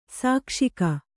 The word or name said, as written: ಸಾಕ್ಷಿಕ